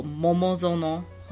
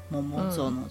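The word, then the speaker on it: 桃園: 桃园这个站呢，在日本也有一个同名的站，叫做 Momozono，好 Momozono，就是桃园这两个字的发音。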